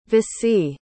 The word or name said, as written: vicie